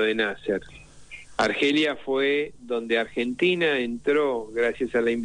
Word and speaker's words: Argentina: El argentín, no, la traducen directamente, lamentablemente tuve que insistir en que se tenía que pronunciar como lo pronunciamos nosotros, Argentina, y no como se pronuncia en inglés, Argentina.